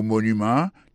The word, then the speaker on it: monument: Pour mot monument, il tombé sur premier syllabe là. The Washington Monument. Monument, c'est comme ça on dit monument en anglais.